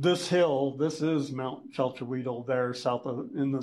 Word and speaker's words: Chalchihuitl: So in this area of the Cerrillos Hills is Mount Chalchihuitl, correctly pronounced.